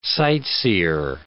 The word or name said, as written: sightseer